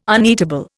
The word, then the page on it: uneatable: Indian